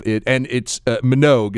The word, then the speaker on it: Minogue: And I look it up, and sure enough, Minogue is how it's pronounced, by the way.